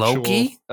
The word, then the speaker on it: Loki: The Locky. – What's the Locky? – The Locky, you know, the one with the horns and he's bisexual. – Loki? – Yeah, I guess if that's how you pronounce it.